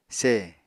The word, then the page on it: se: Apatani